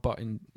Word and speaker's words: Bottin: It's a clever thing that Rob Bottin, apparently, is the correct pronunciation, who was actually only 22 when he started doing the special effects for this film.